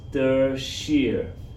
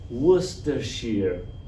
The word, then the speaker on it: Worcestershire: Worcestershire. Worcestershire. Worcestershire.